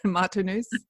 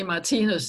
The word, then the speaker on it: Martinus: And I hope I'm saying it correctly, Martinus. – Well, actually, in Danish, we say Martinus. So we stress the I.